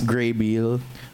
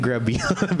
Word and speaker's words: Grabeel: Of course, starring Zac Efron, Vanessa Hudgens, Ashley Tisdale, Lucas Graybeal. We called him Grabeel before.